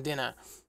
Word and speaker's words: DeNA: And Dena. D-E-N-A. I don't know how you say it. – D-E-N-A. Dena.